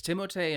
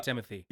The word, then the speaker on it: Timothée: Uh, we meet Paul, who's played by uh, Timothée Chalamet. Or is it? I've heard it's Timothée. It's Timothée in, in French. I. but but people I, just I'm, say Timothy.